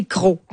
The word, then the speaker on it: croc: » Alors, c'est croc comme prononciation.